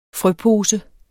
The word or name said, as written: frøpose